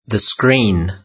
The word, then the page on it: the screen: British English